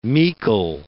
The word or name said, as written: meikle